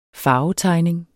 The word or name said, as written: farvetegning